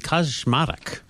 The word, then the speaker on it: Kaczmarek: Jan A.P. Kaczmarek. – Very well pronounced.